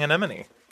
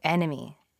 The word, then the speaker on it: anemone: Wait, how am I saying it wrong? Oh, enemy. – Anemone.